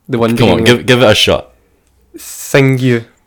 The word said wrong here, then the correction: Tsinghua: All right, that was a terrible. that was a terrible How do you say um, it? It's, it's pronounced Tsinghua.